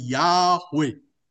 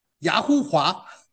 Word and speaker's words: 亚伟: 他说，耶和华中文的翻译呢，Jehovah 啊，那其实我们读原文的人知道说，用近东的各地方的发音，大家能够结合起来，应该正确的发音应该是亚伟，亚伟。他却不懂希伯来文，以为那个 verb 放在中间就发音成 who，所以他说不对，应该要称叫雅呼华，所以叫胡说嘛，叫雅呼华。